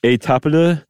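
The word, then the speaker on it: Étaples: But there, you know, there was a flu outbreaks over there in Europe, particularly at one called Etaples. I think I added a little extra mustard on that, but that's generally how you say it.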